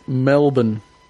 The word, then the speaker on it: Melbourne: People in America always say Melbourne because they're trying to pronounce it, but it's just Melbourne. Melbourne. Just say Melbourne. Melbourne. – Melbourne. – And then you'll Melbourne. – Sound like an Australian.